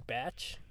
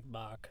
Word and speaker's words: Bach: It's actually pronounced King Batch. He's not like Bach. He's not sophisticated enough to be like Bach.